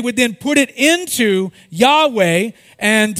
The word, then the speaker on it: Yahweh: However, in the 19th and 20th centuries, biblical scholars began to pronounce Yodhead Wadhead as Yahweh.